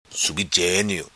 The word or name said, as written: Subi Genio